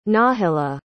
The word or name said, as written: nahilla